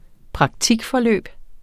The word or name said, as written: praktikforløb